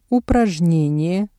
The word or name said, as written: упражнение